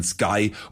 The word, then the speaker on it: Sky: Natürlich, man braucht kein Skü oder Dotzen. – Allmächt, heute wieder lauter Profis am Start. Das eine heißt nicht Skü, sondern Sky und das andere spricht man The Zone aus.